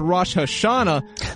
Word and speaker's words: Rosh Hashanah: When Ariel took a Monday off for Rosh Hashanah, there was a... – Yes. – I say it wrong? – Oh, you nailed it.